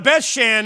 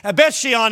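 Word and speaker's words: Beth Shean: We had the privilege to go to Bethshan, and Bethshean as it is pronounced now, but they hung their bodies there as a disgrace.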